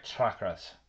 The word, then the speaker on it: chakras: And then we're going to go through each of those crystals related to the chakras. – Yes. – Did I say that right? Chakras.